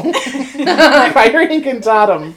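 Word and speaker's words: Priori Incantatem: When you said Incantatum, I was like, later, please do not say it like Tatum O'Neill. Priory Incantatum. And then learning about that it's Fox Feather in both of their wands and stuff.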